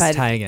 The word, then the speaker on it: Teigen: He did the tree, and then right after the tree, he and Chrissy Teigen, or Teigen, I believe it's actually pronounced. – It is Teigen. – It's Teigen, yeah.